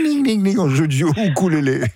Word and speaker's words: ukulélé: Alors normalement on dit ukulélé. Le U en océanien se prononce ou, bon. Donc normalement on devrait dire ukulélé, okay. C'est la prononciation anglo-saxonne du mot.